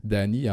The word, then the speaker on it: Dhani: À bientôt 40 ans, Danny Harrison, Danny, en passant, ça s'écrit D-H-A-N-I.